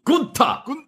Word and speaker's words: Gunther: The announcer goes, like, Fabian Aichner, Marcel Bartel, and Gunther, and he goes, nein. It's pronounced Gunther.